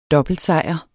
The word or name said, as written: dobbeltsejr